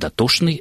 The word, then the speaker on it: дотошный: слов, в которых сочетание «чн» в старых употребительных словах произносится как «шн». То есть «дотошный» — это бывший «доточный».